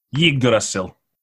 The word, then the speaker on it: Yggdrasil: Yeah, I was gonna chime in with finding our place in Yggdrasil. – How do you say it? – Yggdrasil. – I've always wanted to hear how it sounds.